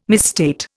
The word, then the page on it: misstate: Indian